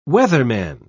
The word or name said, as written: weatherman